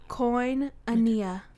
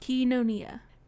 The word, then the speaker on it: koinonia: The word is koinonia. – Wait, what? – koinonia, also pronounced kinonia.